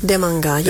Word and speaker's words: Demanga: We won't go there just yet, but, you know, Demanga. Am I saying that right? – Demanga, yeah.